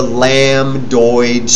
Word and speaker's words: lambdoid: It's called the lambdoid suture. The B is silent.